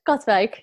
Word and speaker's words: Katwijk: Yeah, almost. Katwijk, yeah. But in English, it's very hard, yes.